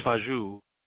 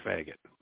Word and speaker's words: Fagaut: And now before you call me homophobic on Gay Pride Month, his actual, how you spell his last name is F-A-G-A-U-T. It's Fajou. French, ironically, but as an American that reads American spelling, when I pronounce it out, it's Dr. Faggot, not Fajou, it's Faggot. That's just how you say it.